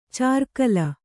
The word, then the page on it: ಚಾರ್ಕಲ: cārkala